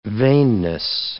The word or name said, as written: vainness